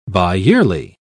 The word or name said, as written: biyearly